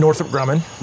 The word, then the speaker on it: Northrop Grumman: Northrop Grumman.